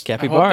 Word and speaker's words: Capybara: This game was developed by, I cannot pronounce this, Capybara Games. – Capybara, that's yeah. right.